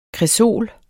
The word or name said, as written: kresol